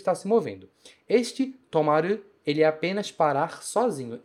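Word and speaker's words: tomaru (止まる): Vou colocar o kanji, tomaru, significa parar, e a leitura é tomaru. A parte que está dentro do kanji, ela se pronuncia to, e o resto fica maru, do lado de fora.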